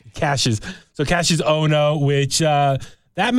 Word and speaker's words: Cassius: Did I say it right? – Cassius, sorry. – Like Clay. – Sorry, my nose is plugged. Clay. Cassius Clay.